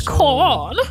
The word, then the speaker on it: Kahan: Hyvä, kun mäkin oon aina sanonut Noah Kahan, ja sit I don't want to be this guy, but it's Kahan.